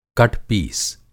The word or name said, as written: कटपीस